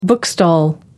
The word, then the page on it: bookstall: American English